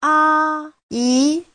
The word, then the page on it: 阿姨: a1yi2